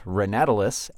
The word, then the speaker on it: ranatalus: And I'm your guest for today, Steve, ranatalus, at ranatalus on Twitter. – So how do you spell that? – R-A-N-A-T-A-L-U-S.